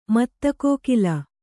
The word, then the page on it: ಮತ್ತಕೋಕಿಲ: Kannada